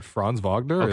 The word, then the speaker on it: Franz Wagner: Can you pronounce these, for me? – What, Franz Wagner and Paolo Banchero? – Yeah, perfect.